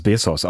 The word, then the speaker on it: Bezos: Da haben die Besitzer der Washington Post ihre Zeitung nach 80 Jahren im Familienbesitz jetzt an den Amazon-Gründer Jeff Bezos verkauft. Ich hoffe, der spricht sich überhaupt so aus. – Ich glaube, es ist Bezos. Also Bezos. So ein bisschen wie Küssen auf Spanisch.